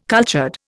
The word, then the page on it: cultured: Indian